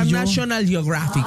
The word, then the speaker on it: National Geographic: se publicó el primer ejemplar de la revista National Geographic. – Oh, my God. Ay, lo pronunciaste bien bonito. – National Geographic.